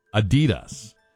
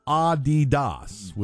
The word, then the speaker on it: Adidas: And America's and Americans are pronouncing Adidas wrong, or as we say, Adidas. It is supposed to be Adidas, with the emphasis on the first syllable, not Adidas, as we say, with the emphasis on the second syllable.